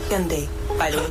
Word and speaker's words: Hyundai: Hyundai does not sound like Sunday.